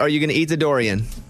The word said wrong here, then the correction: durian: A durian? – Durian.